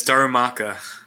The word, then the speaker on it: Darumaka: How can you never pronounce that name right your first try? – Did I just fuck it up? – Yeah. – Yeah, it's Darumaka.